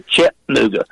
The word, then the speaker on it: Chattanooga: Glad to hear you say it correctly. A lot of people not from here will say Chattanooga.